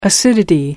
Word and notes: acidity: American English

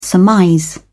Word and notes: surmise: British English

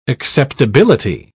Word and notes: acceptability: British English

